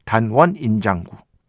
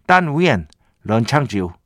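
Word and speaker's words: 딴 위엔 런창지우 (但愿人长久): 왕페이의 단원 인장구 한국식 중국어 발음을 하면 이렇게 됩니다. 한자로 발음하면 단원 인장구. 청학원대 바라건대, 오래오래 사시길 뭐요 정도로 생각하시면 됩니다. 중국어로 하면 딴 위엔 런창지우 아, 요 정도 됩니다.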